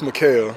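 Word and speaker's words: Mic'hael: It's Mikhail. – It is Mikhail. Yeah, it's spelled M-I-C apostrophe H-A-E-L. Most people don't put the apostrophe, so end up looking like Michael.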